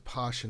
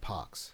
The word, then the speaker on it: pox: I guess they found out early to call it to pronounce it posh and not pox.